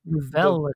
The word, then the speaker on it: Velle: So it's spelled V E L L E, but I have I, I get the impression they want you to pronounce it Vella.